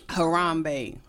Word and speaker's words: Harambe: Harambe. – Harambe.